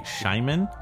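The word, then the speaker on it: Schyman: This composer I've never heard of before, Gary Scheiman, I believe is how you pronounce his name. It's S-C-H-Y-M-A-N, yeah.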